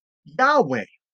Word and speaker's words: Yahweh: Now, most scholars believe, and I agree with this, it was actually pronounced Yahweh, Yahweh.